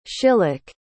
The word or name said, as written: shilluk